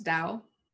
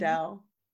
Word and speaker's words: Tao: I think it's Dao. – Yeah, T-A-O is usually mm-hmm. Dao. Because I know that Dao De Jing is a mm-hmm. du, but it's spelled with a tu.